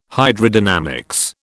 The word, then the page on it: Hydrodynamics: British English